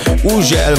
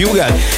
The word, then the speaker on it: Hugel: Így van, az úriember, akinek minden országban másképp ejtik a nevét, valahol Uzsel, valahol Hügel, valahol Ugel, meg mindenféle kép, de nálunk úgy mondjuk, hogy Hugel, és Salto és a Jamaican Bam Bam érkezik, hogy csavarjátok fel a hangerőt.